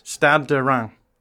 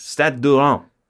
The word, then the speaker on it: Stade de Reims: Stade Ram. – Ran. Are you ending with an N or an M? – If it sounds like an N, but it is an M-S. – Stade de Reims.